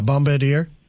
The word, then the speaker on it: Bombardier: Yeah, Bombardier.